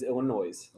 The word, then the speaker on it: Illinois: Also, it is Illinois. – Yep, I'll agree with that. – It is not Illinois. It is Illinois. – Illinois. – It annoys me. How, yeah. Okay. The people don't say Illinois.